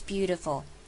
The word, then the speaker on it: beautiful: Because the most beautiful, 好 ,most, 一样把什么省略，把尾音 T 给省略，只要发 most 就好， most beautiful, 这样就不要念成 most beautiful, 这样太 K 了。 OK, beautiful, 要念得好听，其实这个字我们讲过很多次咯，在 TI 身上， TI 把它念成 DI, beautiful, 这样就会是好听的，不要念成 beautiful, 也不要念 beautiful, 念 beautiful 的的的。